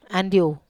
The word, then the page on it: andiu: Wamesa